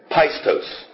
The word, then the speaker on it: pistos: And it is, and I'm going to do my best to pronounce it from the Greek form, it's pistos. P-I-S-T-O-S.